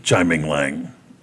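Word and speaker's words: Tsai Ming-liang: From, and I always say his name wrong, but whatever. Chai Ming Lang, I just blanked. That name is spelled TSAI.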